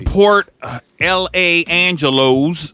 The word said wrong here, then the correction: Port Angeles: or however it's pronounced. – Port Angeles.